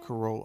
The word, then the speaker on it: Carolla: Carolla.